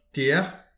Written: tier

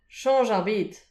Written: chanch ar bed

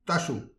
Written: tachou

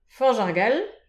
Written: fanch ar gall

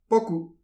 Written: pokou

